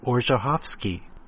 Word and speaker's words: Orzechowski: According to the internet, it looks like the correct pronunciation is actually Orzechowski, or something approximating that.